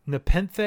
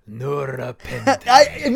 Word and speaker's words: Nepenthe: So for me, Nepenthe, is that how you say it? – Yeah. – Whenever I see it, I want to say Nurapenthe.